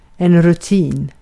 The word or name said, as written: rutin